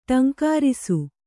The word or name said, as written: ಠೇಂಕರಿಸು